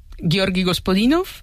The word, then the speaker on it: Georgi Gospodinov: Ayúdanos a pronunciar, los autores. – A ver, Georgi Gospodinov, ¿sí? – Zdravka Eftimova y Vera Mutavcheva.